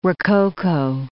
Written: rococo

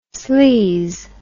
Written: sleez

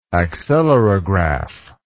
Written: accelerograph

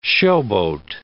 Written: showboat

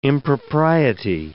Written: impropriety

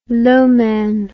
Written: Loman

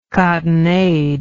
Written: cottonade

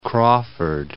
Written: Crawford

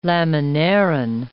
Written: laminarin

